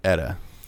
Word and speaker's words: Eta: This is Eta, right? – Eta. We believe that's the way I believe you pronounce it.